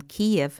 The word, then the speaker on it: Kiev: Yes, I was born and raised in Kiev, and I pronounce it in the Russian way, not in the Ukrainian way.